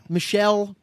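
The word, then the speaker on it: Michel: Michael or Michelle? I can't know how you say his name. Michelle.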